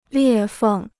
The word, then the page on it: 裂缝: liè fèng